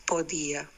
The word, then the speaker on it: podía: Podía, then it's podía. P-O. Podía.